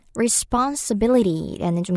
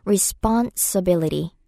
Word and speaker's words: responsibility: responsibility라는 좀 긴 단어가 나왔죠. 책임, 의무라는 뜻인데요. 이음절의 강세가 있어서 펀 발음할 때 좀 길게 해보세요. responsibility, responsibility.